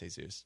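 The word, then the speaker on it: Jesús: Technically, it's pronounced Jesus.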